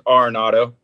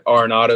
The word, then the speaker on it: Arenado: Yeah, well, last name's Arenado. I mean, you can call it whatever you want. You know, it's all good. But Arenado is how you say it.